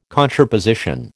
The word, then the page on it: contraposition: American English